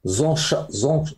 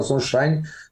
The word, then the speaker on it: Zonshine: A crise se complicou mais ainda quando o embaixador de Israel no Brasil, Daniel Zonshine, creio que se pronuncia assim, Daniel Zonshine, disse sobre a suposta presença do Hezbollah no Brasil.